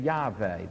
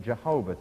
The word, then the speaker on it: Yahweh: it's the name Yahweh, the tetragrammy, YHWH, Jehovah, the mispronunciation.